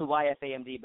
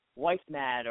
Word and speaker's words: YFAMD: It's, it's spelled YFAMD, but it's pronounced YFAM. And uh, a lot of people okay. mispronounce it. – Why they, they they'll be like wife mad or why sad or they they somehow miss a letter or add a letter.